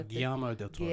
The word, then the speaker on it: Guillermo del Toro: Bradley Cooper, Toni Collette, Rooney Mara, Cate Blanchett, directed by the name I can never pronounce, Guillermo del Toro. De oh, Guillermo del Toro.